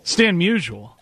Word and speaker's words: Stan Musial: but is it Stan Musial? – Stan Musial.